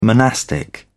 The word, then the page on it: monastic: British English